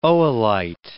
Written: oolite

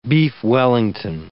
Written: beef Wellington

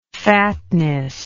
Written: fatness

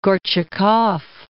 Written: Gorchakov

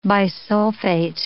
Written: bisulfate

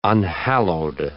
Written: unhallowed

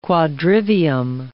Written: quadrivium

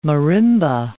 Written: marimba

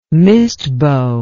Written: mistbow

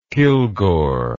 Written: kilgore

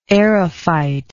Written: aerophyte

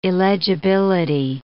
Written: illegibility

